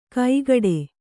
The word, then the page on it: ಕೈಗಡೆ: kaigaḍe